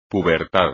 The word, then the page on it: pubertad: espagnol